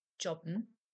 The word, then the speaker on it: jobben: Jobben versus arbeiten. Both jobben, pronounced with a hard J, and arbeiten mean to work.